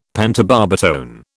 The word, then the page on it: pentobarbitone: British English